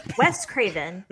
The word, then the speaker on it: Wes Craven: We talked about anthology movies last week on Laser Time, but we got to thank people like Wes Skirvin. I'm saying his name right. Wes Sekervin. Sickervin. – Wes Craven. Thank you, Wes.